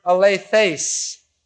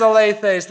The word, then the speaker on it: alethes: And it's, the, it's the, an adverbial form, an adverb form of the word alethes, a L E T H, long E S.